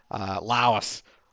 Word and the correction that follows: Lois: God, I'll mispronounce it every time. Lois, Lois Lane, Laos Lane.